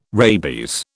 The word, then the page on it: rabies: British English